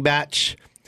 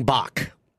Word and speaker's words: Bach: with 30 million followers at King Batch. Not at King Bach. – Yeah. – Definitely. It's spelled B-A-C-H, and a lot of people call him.